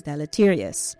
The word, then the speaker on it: deleterious: It has that spelling of delete, and the word is deleterious. It's an adjective, and it is spelled D-E-L-E-T-E-R-I-O-U-S.